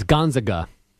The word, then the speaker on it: Gonzaga: Yeah, it's Gonzaga. – I'm just saying they get annoyed when it's pronounced wrong. So I wanted to. – The easiest way to remember is that they're not the Zags.